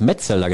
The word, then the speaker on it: Metzelder: Aber der hat auch beim BVB gespielt und er hat gesagt, Metzelder. Und ich sage Metzelder. Was ist denn jetzt die richtige Betonung? also ich habe ihn immer Christoph Metzelder genannt, mit Betonung auf dem ersten Vokal des Nachnamens.